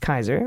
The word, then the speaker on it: Caesar: Caesar is originally pronounced with a hard C. So it's pronounced Kaiser, and that's where the German Kaiser and Russian Tsar come from.